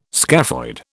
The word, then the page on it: scaphoid: British English